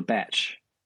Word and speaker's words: bach: Yeah, in New Zealand, we have what's called a batch.